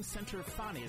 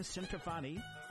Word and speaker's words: Centofanti: But I have a wonderful guest, Jane Centofanti. Is that right? – Centofanti. – Yes. I gotta get the Italian. I gotta get the Italian pronunciation right. But Jane Centofanti, and you and I had worked with Off Broadway West.